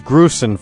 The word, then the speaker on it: Grusin: All right, we'll be right back with the Dave Grusin, and it is Grusin, folks, I've been mispronouncing it for 4 years, Grusin.